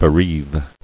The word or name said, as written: Bereaver